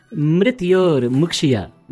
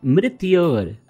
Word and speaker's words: Mrityor Mukshya: Mitror Mushki. – Mitror Mushki. No, it is Mrityor Mukshya. Mrityor. Yes. Mrityor.